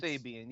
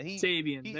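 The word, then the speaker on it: Sabean: So I would be all for Brian Simeon or whatever. I'm butchering his name right now, but the old GM for the Giants. – Sabian, yeah. – Sabian, there we go.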